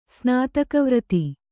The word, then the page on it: ಸ್ನಾತಕವ್ರತ: snātaka vrata